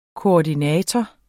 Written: koordinator

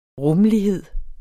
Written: rummelighed

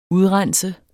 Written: udrense

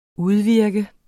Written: udvirke

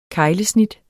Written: KEGLESNIT

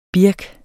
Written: birk

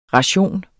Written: ration

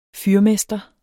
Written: fyrmester

